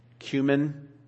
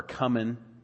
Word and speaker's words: cumin: He said, woe to you, scribes and Pharisees, hypocrites, for you tithe mint and dill and cumin or cumin. There's two kinds of people in the world, those who say cumin, those who say cumin. I've looked it up. They're both right pronunciations.